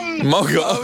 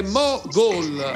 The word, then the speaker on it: Mogol: Mogol. – No, ma hai sbagliato la pronuncia. La pronuncia è Mogol.